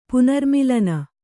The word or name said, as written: ಪುನರ್ಮಿಲನ